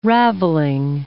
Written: ravelling

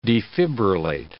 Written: defibrillate

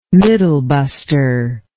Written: middlebuster

